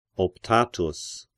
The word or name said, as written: Optatus